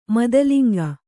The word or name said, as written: ಮಾತುಲುಂಗ